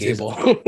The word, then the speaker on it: Gable: That's five teams, like you said, the sixth that 99 James Gobble, uh, or Gabal, excuse me. Uh, actually, I think that might even be mispronunciation, but James Gable.